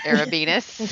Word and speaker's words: Arabinus: Alicia Kay. Arabinus. – Rhymes with? – Penis. Let's just go ahead and put it out there.